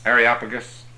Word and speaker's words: Areopagus: And they took him and brought him unto Areopagus. Is that how you say it? Areopagus? – Areopagus.